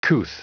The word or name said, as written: couth